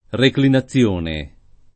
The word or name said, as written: reclinazione